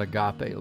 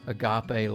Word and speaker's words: agape: Might be a little bit of that in what we discuss, but really we're talking about this love called agape love. It's spelled A-G-A-P-E.